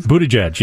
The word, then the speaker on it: Buttigieg: Peter Buttigieg, the young, is that how you say it? – Buttigieg, yep.